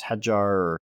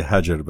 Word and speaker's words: Hagar: And so I don't know exactly how I'm supposed to pronounce it. It's Hagar. – I say Hajar, but that's Arabic. – That's Arabic. So I think I've always heard Hagar. – Hagar is common.